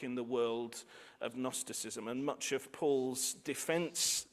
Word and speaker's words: Gnosticism: There's an intellectual threat in and around the community, which we assume based on the things that's written, this thing called Gnosticism, spelt with a silent G, though very much the root of our word knowledge, which has a silent K.